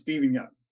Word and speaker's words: Steven Yeun: His name is Steven Yeun. Yes. – Okay. – I listened because I wanted to hear him actually say how to pronounce his name. That's how you say it.